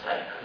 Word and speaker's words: Steiner: Steiner, aunque se escribe Steiner, pero como el apellido es alemán, Steiner, ¿no?